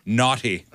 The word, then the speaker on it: Naughty: I'm sorry, I'm saying that wrong. Naughty. Naughty America.